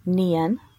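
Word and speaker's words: Nion: It is the third consonant of the Ogham alphabet, and it's pronounced Nian.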